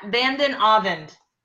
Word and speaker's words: Vanden Avond: Matt Vanden Avond.